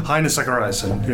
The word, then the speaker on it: Heini Zachariassen: Heini Zachariassen, yeah.